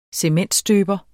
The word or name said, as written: cementstøber